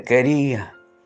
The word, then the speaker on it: quería: Varias décadas después, el mundo Rivero se permitirá cambiar a sabiendo que te quería, ¿eh? como corresponde, la, con el acento en la E y no en la O final.